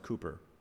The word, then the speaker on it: Cowper: William Cowper, it's spelled Cowper, but it's pronounced Cooper.